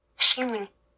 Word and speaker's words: Xiong: Like it's H-S-I-U-N-G. Xiong. We'll call him Chi. – Do you want me to Google it? – If you like. How do you pronounce Xiong? All right, give it to me. How do we pronounce his name? – Okay, I found a YouTube video of it, so this is how it claims that it's pronounced. – Xiong. – I can't even recreate that.